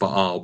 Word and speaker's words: Baal: You know, in the word Baal, you know, Baal, you know, sometimes we say Baal, it's actually pronounced Baal, but the word Baal means to rule over or to marry or to possess or to own.